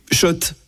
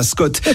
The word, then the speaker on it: Schott: Oui, Schott. Et pas Scott, ça fait des années qu'on prononce mal la marque.